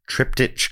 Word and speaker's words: triptych: We know it's pronounced triptych. Now we do. For a couple of years we didn't, but that was because Dave said it wrong on episode 1.